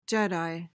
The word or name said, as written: jedi